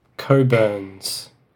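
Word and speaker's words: Cockburn's: COVID? – Coburn's. It's pronounced Coburn, the name in the title, but spelt Cockburn.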